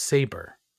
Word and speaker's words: Sabre: Sabre? – Sabre. – Saber. It's pronounced Saber.